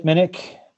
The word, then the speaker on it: Minnich: Well, I can be found on Twitter at Chip Minnick. My last name is spelled M-I-N-N-I-C-H.